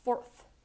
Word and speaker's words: fourth: The next mispronounced word is fourth. Fourth with a th sound at the end.